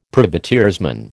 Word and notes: privateersman: American English

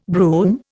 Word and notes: broom: Indian

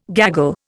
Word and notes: gaggle: Indian

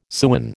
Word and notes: sewen: American English

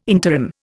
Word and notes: interim: Indian

